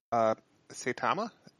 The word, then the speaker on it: Saitama: So we open up, we're at our main protagonist's apartment, Saitama.